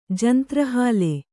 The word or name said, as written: ಜಂತ್ರಹಾಲೆ